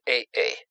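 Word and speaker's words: AA: Китайская фамилия произносится как Ай. Личное имя А-А произносится как Эй-Эй.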